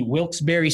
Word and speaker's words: Wilkes-Barre: He right now plays for the Wheeling Nailers, played a couple games with, the Wilkes-Barre Scranton Penguins.